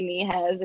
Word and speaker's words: Nehez: Nehez, if you do want to follow me on Instagram or just creep on my Instagram, I don't care.